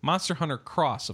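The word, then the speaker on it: Monster Hunter X: And it's not Monster Hunter 10. It's Monster Hunter Cross, of course, as we know from Japan. Anytime you see an X, pronounce it as cross.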